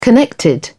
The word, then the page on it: connected: British English